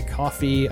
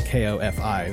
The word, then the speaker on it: Ko-fi: I originally called it coffee when I first encountered those URLs, but because it's like, buy me a coffee, you know? Mm-hmm. But it's spelled K-O-F-I, so it's, people say Ko-fi because it's got the dash in it.